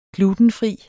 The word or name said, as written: glutenfri